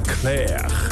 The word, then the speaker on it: Clerc: Nou ja, het is in ieder geval een grote hit uit 1974, zoals ik al zei, van Julien Clerc. En dan spreek je die laatste C ook weer niet uit.